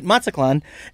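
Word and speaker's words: Mazatlán: and they weren't saying it right, and I was saying it right, Mazatlan, and so they were.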